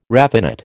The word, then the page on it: raffinate: American English